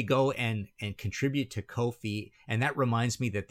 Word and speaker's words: Ko-fi: Ko-Fi rhymes with no fee.